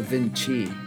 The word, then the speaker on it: Vinci: When I tracked that song, I sang it as Leonardo da Vinci. I did the C thing, okay? – Fancy. – But I realized that was wrong, and it's actually pronounced Chi. Da Vinci.